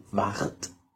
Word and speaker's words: Ward: Ich weiß nicht, wie ich es richtig ausspreche. Also Ward. W-A-R-D. – Ward. – Ja. Ward. Genau, Ward 8.